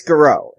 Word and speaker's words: garrote: It's Garrote. The T is silent. T's are silent.